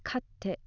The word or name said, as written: katte